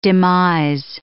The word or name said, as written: demise